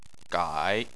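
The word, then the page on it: gai3: tone 3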